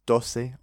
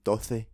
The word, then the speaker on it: doce: So dos, D-O-S, and doce, or in Spain, doce. And that's D-O-C-E.